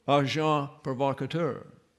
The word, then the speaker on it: agent provocateur: So, agent provocateur, and it's got an S on the end, but it is not pronounced.